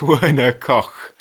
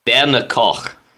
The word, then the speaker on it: Werner Koch: CH in German, is that a sound? – Yeah, it's more of a harsh sound. Give it a go. Try his name. – That's cruel. – I don't even know what his name is, so I'm going to. – If I'm pronouncing it correctly, and I apologize if I'm not, it's Werner Koch.